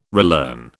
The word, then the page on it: relearn: British English